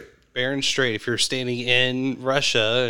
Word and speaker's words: Bering Strait: Because if you were standing on the other side, because you're talking, I'm going to butcher it, Beringer Strait. – The Bering Strait, the Beringer Strait, the Bering Strait.